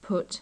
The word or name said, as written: put